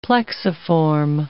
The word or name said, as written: plexiform